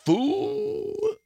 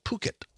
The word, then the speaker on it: Phuket: It turns out in Thailand, P-H is pronounced with puh. And the U is ooh. The city is called Phuket.